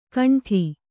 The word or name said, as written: ಕೊಂಡಿ